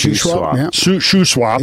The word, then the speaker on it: Shuswap: Shuswap.